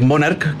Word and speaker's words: Monarch: Ya con Juan Mañasco y Atilio Mañasco, que compraron una fábrica pequeña que estaba quebrada, que se llamaba Monarch. Ya, viene de origen italiano. Ya la CH en Italia se pronuncia como una K. Entonces es Monarch, que viene de Monarca o el rey de los calcetines.